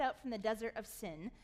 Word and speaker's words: Sin: and that doesn't mean sin, that was a Hebrew word, they'd pronounce it seen, we just say sin.